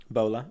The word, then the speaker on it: Bhola: Bola. B-H-O-L-A.